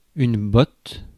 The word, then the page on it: botte: /bɔt/; France